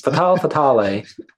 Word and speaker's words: Fatale: Fatale. I know it comes from femme fatale.